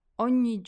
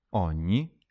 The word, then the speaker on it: ogni: Ogni is spelt O-G-N-I. But we don't say ogni. Here we've got the G-N sound combo, which is pronounced like ny, like the ny in lasagne.